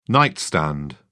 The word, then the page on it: nightstand: British English